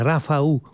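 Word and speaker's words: Rafał: si intitola Rafaou. Questa è la pronuncia, sembra corretta, del nome, che è scritto Rafal con un trattino sulla L, e Blechacz è il suo cognome, anche questo dovrebbe essere la pronuncia più o meno corretta, speriamo perlomeno.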